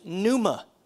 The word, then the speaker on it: pneuma: Now that word spirit right there in the original language, the original Greek language, is pneuma. Pneuma. Everybody say pneuma. Pneuma. It starts with a P, but the P is silent.